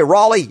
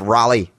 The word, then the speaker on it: Raleigh: like, don't say Raleigh, it's Raleigh.